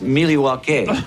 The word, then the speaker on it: Milwaukee: Actually, it's pronounced Milwaukee.